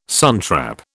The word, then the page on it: suntrap: British English